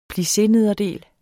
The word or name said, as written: plissénederdel